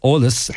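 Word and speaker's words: eolas: It's E-O-L-A-S at isfagerlinn.ie. That's Olus at isfagerlin.ie.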